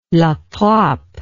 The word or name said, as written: latrappe